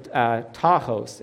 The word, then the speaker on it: tachos: But the Greek word for soon, it's the word tachos. It's spelled T-A-C-H-O-S. T-A-C-H. It looks like tachos.